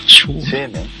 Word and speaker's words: 正麺: あれは、なんて読むんでしょう。 – 正麺。正麺。正麺、正麺。ちゃんと見とかなかったな。正しい麺と書いて、はい。